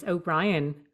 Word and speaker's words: O'Brien: And my surname is O'Brien, O-B-R-I-E-N.